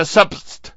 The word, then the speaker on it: subst: It is substitution, except that the command that was used in the old DOS days is S-U-B-S-T, subst, and so we must pronounce it that way to be true to Microsoft.